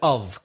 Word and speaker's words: of: Be careful of, it's O-F, not O-double-F. There is a difference in pronunciation with those two prepositions. When there's a double F, O-double-F, off, there's no vibration in the throat. It's not voice, not in a voz con vibración.